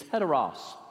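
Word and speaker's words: heteros (ἕτερος): it makes an H sound. You got to put the H in front of it. If it's not, it's a silent sound. So those of you reading your Greek, the actual pronunciation is heteros.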